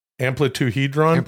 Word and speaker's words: amplituhedron: In 2013, scientists discovered the amplituhedron. Am I saying that right? Amplituhedron? – Amplituhedron, yeah.